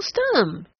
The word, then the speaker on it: Stumm: "Why, it's Colonel Stumm," he cried. He pronounced it like the first syllable in stomach.